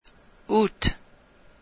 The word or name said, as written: aout